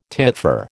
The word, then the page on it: titfer: American English